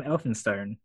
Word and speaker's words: Elphinstone: Yeah, that's spelled E-L-P-H-I-N stone.